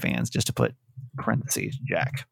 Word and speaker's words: Jacques: Oh, it's Jack. – Oh, it's Jack. – Yep. Jack. Okay. We're going with Jack.